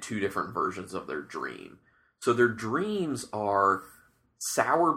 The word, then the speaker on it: Jream: So the next beer we're going to do is one of their Dreams. Now, this is Dream, not spelled with a D, but spelled J-R-E-A-M.